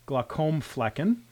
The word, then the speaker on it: Glockenflecken: And so this was Dr. Glockenflecken. I think I put an extra syllable in there. Glockenflecken.